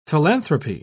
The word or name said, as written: philanthropy